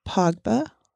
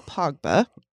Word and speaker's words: Pogba: Score with Pogba, I believe is how you pronounce his name. – Oh, that's his name? Okay, yes, I thought it stood for something. – It's Paul Pogba.